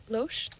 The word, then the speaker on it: Lausche: Lausche is L-A-U-S-C-H-E. And Lausche? – Lausche. – Is that how it's pronounced?